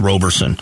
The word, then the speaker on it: Roberson: There is a student, a junior at ASU, Alexia Robertson is her name, or Roberson. I'm sorry. I think it's pronounced Roberson. There's no T in there.